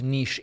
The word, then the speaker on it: niche: And by the way, I understand that both niche and niche are acceptable pronunciations in 2023 for that word.